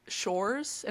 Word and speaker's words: Sjors: but it's Shores and it's spelled S J O R S.